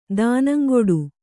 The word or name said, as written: ದಾನಂಗುಡು